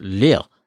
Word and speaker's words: Lire: If you are interested in Leary, and its developer tells me that you pronounce it Lille, it's French, you know, but it's spelled L-I-R-E.